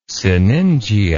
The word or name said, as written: sinningia